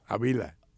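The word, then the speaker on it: Ávila: Avila. – Avila. – No, no, porque ellos no pueden decir palabras de esdrújulas.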